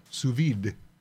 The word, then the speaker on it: sous vide: I know it's pronounced sous vide, but it looks different.